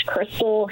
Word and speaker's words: Chrystal: It's Crystal Hammer, and it's spelled C-H-R-Y-S-T-A-L, just like the stripper.